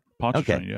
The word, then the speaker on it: Pontchartrain: It was about a month into the investigation when investigators were able to get surveillance footage of Lake Pontchartrain. And I always feel like I'm mispronouncing that. – No, that's right. Pontchartrain.